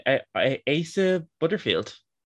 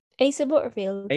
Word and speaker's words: Asa Butterfield: Asa Butterfield. – Asa Butterfield.